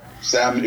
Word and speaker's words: salmon: but if you pronounce salmon, if you pronounce the L in salmon, that's it. We can't be friends.